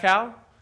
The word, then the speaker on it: Dachau: One of the first places we visit is Dachau, I think it's how you say it. What is it? – Dachau. – Dachau.